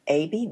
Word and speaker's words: Athey: Athey, did I say that right? – Athey.